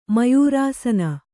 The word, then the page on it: ಮಯೂರಾಸನ: mayūrāsana